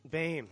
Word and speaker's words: Boehm: As far as working with Doug Boehm. – Bame. – Okay, I wasn't sure how to pronounce it. I don't think I've ever heard somebody say his name, so I wasn't sure. But I always said Boehm.